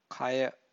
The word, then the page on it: kaya: Pali